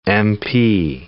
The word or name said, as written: MP